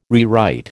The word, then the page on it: rewrite: American English